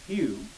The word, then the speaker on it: HEW: Its principal establishment is that college of priests called HEW, pronounced Hugh, as you know,